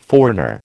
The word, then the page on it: foreigner: American English